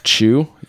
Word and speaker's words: Chew: Went, I don't know how to say his name. That's why we keep calling him the CEO. But he went on some Mr. Chew. – Mr. Chew, yeah.